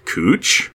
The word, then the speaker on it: Couch: You mean the word that spells like couch, yet is pronounced like cooch? – That is correct, sir.